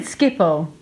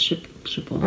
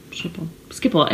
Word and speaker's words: Schiphol: Is that how you say it? Schiphol? – Yeah. – I always said Schiphol. – No, it's Schiphol. – Okay. – Schiphol. Everyone says Schiphol. I don't know why.